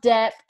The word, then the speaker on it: debt: something about debt. And he says debt like with the B. Yeah. Debt. the B is silent, but um, yeah, I just want to teach him.